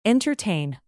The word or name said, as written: entertain